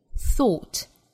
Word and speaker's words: thought: 那我们尤其要注意 thought 这个词英音的读法，应该是长元音卷元音的 o thought thought，而这个词美音呢读成 a thought thought。看到 th，我们要把舌尖下意识的伸到口腔外面一点点，去摩擦上排牙齿发 thought 和 thought。